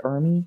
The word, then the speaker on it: Ermey: Is it Ermey? Is that how you pronounce it? E-R-M-E-Y? – I'm going to go with that.